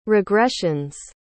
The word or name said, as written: regressions